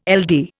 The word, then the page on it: LD: Indian